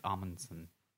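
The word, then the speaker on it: Omundson: It is close to ombudsman, but it's not. I think it's Amundsen.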